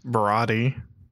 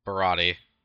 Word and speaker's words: Baratie: Baratie, whatever, however it's pronounced. – Baratie. – Baratie. – Mm-hmm. That's how I've always heard it pronounced. Baratie.